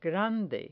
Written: grande

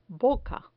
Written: boca

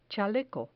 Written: chaleco

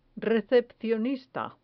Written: recepcionista

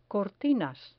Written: cortinas

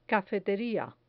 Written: cafeteria

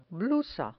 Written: blusa

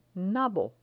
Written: nabo

